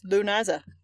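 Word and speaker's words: Lughnasa: Doesn't look like you should be able to say that word. It's Lunaza.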